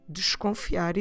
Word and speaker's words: desconfiar: D-E-S-C-O-N-F-I-A-R. Desconfiar. Notice that that first syllable, dish, we don't need to pronounce it in a very open and clear way. We don't need to say desconfiar. We can just go slightly through that syllable.